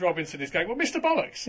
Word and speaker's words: Bolix: And one of those episodes featured a character whose name is spelt B-O-L-I-X. – Yes, Mr. Bollocks.